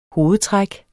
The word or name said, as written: hovedtræk